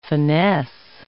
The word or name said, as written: finesse